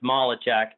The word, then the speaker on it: Malecek: Well, everybody in my family except so my two brothers and my sister, everybody in my family says it's Malecek and my nephew wrestled at the Air Force Academy when you came last year.